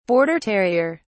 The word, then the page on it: border terrier: British English